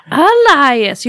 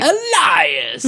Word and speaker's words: Elias: Elias. You have to say his name right. – Elias.